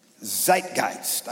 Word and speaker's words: Zeitgeist: The word is Zeitgeist. Zeitgeist. I looked it up, and that's the way you say it.